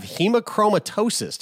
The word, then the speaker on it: hemochromatosis: Justin, you have hemochromatosis. Did I pronounce that right? That's correct. Nailed it.